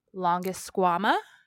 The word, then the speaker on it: Longisquama: So, Drew, whose favorite Pokemon is the Turtonator, asks a little bit about, I'm going to screw this word up, sorry, Longisquama.